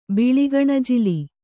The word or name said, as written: ಬಿಳಿಗಣಜಿಲಿ